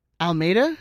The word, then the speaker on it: Almeyda: Almeyda. – Yeah. – Oh man. – Am I pronouncing um, that dude's name right? – Yeah. I mean, I'm gonna say yes because he outcoached GBS and we smashed LAFC.